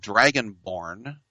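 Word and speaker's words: Dragonborne: There's an RPG in the PC called Dragonborn, with a silent E.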